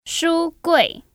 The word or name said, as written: ShuGui